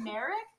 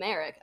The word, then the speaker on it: Marek: M A R E K. – Ooh, is it spelled with an I? It's like Derek, but with an M.